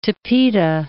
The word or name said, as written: tapeta